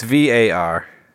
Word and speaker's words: VAR: Hey, is that pronounced VR? I thought no. It was VAR. – It's VAR.